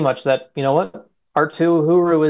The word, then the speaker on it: Arttu Heuru: and it's pronounced Arttu Heuru is how it's pronounced. Arttu Heuru is going to probably be in the mix to make the roster out of camp.